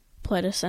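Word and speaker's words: Pledis: More about 17, though, is their company is Hybe Labels under Pletus Entertainment, I believe it's pronounced. There's so many weird names in this, because Pletus is spelled P-L-E-D-I-S, I believe.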